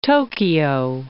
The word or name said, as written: Tokyo